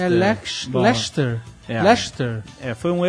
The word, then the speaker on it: Leicester: Se pronuncia Lester. Como o nome do ratinho ajudante do mundo de Bigman, Lester.